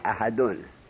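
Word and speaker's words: أحدٌ: اگر وصل کردی احد را به الله، باید تنوین را تلفظ کنی، تنوین احدون.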